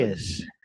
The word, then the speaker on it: Arraez: Arraez.